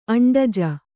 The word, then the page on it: ಅಂಡಜ: aṇḍaja